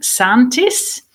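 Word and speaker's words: Säntis: Now, I'd like to ask you a bit about, and excuse me if I pronounce it incorrectly, but Mount Santis. Now, it's a mountain. – Very good.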